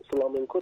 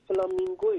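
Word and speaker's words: flamenco: اصل اصلا این کلمه از اینجا گرفته شده که فلامنکو تلفظ اسپانیایی عبارت است، منتها به خاطر تکرار زیاد و کشورهای مختلف بله شده فلامینگو. – بله بله. ولی فلامینگو اشتباهه.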